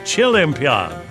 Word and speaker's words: Chilimpiad: Well, it would be like Olympiad, but so with chili. – Chilimpiad.